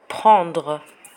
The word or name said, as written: prendre